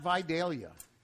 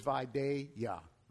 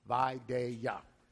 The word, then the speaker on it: Vidalia: One thing that drives people crazy is the mispronunciation of the sweetest onion on earth. It is not Vidalia. It is Vidalia. Vidalia.